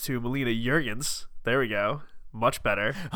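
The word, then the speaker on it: Juergens: How were we saying it last time? – Jurgens. It was terrible. I was going to hard J. I needed to go to a soft J, like yogging.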